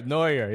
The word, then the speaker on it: Neuer: Neuer, yeah.